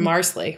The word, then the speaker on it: Marsali: Uh, and we find out he was making a little potpourri from Marsali, rhymes with parsley nice.